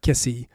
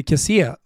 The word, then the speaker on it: Kessie: Så det finns ingen anledning att säga Kessi när han heter Kessie för att det är det som är den enkla regeln.